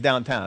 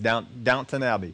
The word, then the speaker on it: Downton Abbey: Downton Abbey? Is that how you say it? Downton? I think it's Downton Abbey. I don't think it's downtown. I think it's Downton Abbey.